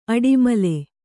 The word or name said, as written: ಅಡಿಮಲೆ